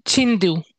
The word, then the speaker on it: Chinedu: I would pronounce it Chinedu. – Ah, okay. What if you pronounce the E like an A? – Like an A. Chinedu? – Exactly. Chinedu.